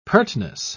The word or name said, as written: pertness